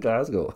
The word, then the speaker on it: Glasgow: Sorry, I said it wrong. In Glasgow, Scotland. Is that all right, mate? Glasgow.